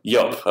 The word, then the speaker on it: Joop: And earlier this year, Mediakraft Netherlands was purchased by the daughter of the legendary Animal co-founder Joop. Am I pronouncing that correctly? – Joop. – Okay, yeah.